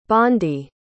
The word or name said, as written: bandi